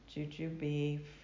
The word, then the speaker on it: jujube: Nerve support using synergistic herbs includes extracts from passion flower, Icelandic moss, jujube fruit, did I say that right? – Mm-hmm.